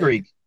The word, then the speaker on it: Greig: Greig. It's Greig. – It's Greek. So let me paint the picture. Here. – To me. – The Ottawa's up on Toronto 4-3, I think. – Yep. – Greig gets breakaway, empty net, does a slap shot, a big old from the hash.